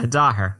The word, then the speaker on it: Daher: Did I get that correctly? – Yeah, Dahir.